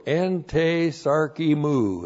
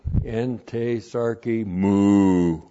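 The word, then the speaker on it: Ente sarki mu (ἐν τῇ σαρκί μου): Ente sarki mu is how it looks.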